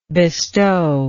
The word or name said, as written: bestowment